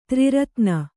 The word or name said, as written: ತ್ರಿರತ್ನಿ